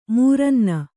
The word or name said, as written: ಮೂರನ್ನ